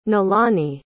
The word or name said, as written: nilani